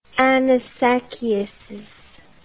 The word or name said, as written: anisakiasis